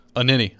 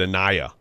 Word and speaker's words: Anini: Anini, I believe, is the pronunciation. – Anini. – David Anini. – Okay, Anini. I mean, most people in the Titans soft press corps call him David Anaya. So, okay, Anini. – It's definitely not that.